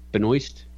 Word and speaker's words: Benoist: But it's always nice to look at Melissa Benoist, is that how you say her name? – Yeah.